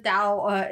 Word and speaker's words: Tao: Actually, and I do think the Tao is sometimes pronounced with a D, sometimes pronounced with a T. I think it's a letter that doesn't exist in the English language is ultimately what it is.